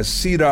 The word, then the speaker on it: Cedar: And then afterwards, he said, yes, that's Mosaic by Cedar R. Walton. He would pronounce it like radar, you know.